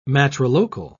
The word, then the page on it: matrilocal: British English